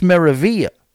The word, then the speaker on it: Meraviglia: We're putting the silence on the L, too. It's Meraviglia. I mean, what are we doing with all these consonants? We're wasting ink on these letters that we don't pronounce.